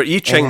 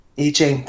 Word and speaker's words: I Ching: Remember, I Ching. That's how you pronounce it. – I Ching, that's right.